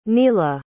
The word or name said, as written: neila